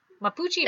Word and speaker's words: Mapuche: The name of the town of Chilean in the Itata region means the place where the sun is in the local Mapuche is how you say it. Mapuche, right? That's how we're going to say it.